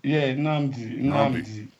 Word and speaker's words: Nnamdi: For those who don't know, Nnamdi, is that the proper pronunciation of your name? – Yeah, Nnamdi.